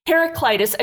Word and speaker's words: Heraclitus: Heraclitus, a Greek philosopher, says change is the only constant in life.